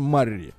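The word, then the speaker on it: Murray: Мы его называем Мюррей, но в Америке он называется Марри. Вот, мы немножко неправильно произносим его фамилию.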